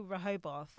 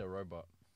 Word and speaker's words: Rehoboth: I say Rehoboth. – I say robot. – Really? – I learned it from Lucky. I say robot. – I just say the, what's it called? The E is silent. – Okay, so those are listening. So guys, I think a unanimous decision is that it's called Rehoboth.